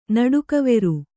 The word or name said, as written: ನಡುಕವೆರು